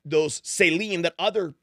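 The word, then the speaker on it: tselem: And so the Hebrew word here is Selim, which we would pronounce sort of like S-E-L-E-M, but it's actually written out T-S-E-H-L-E-M.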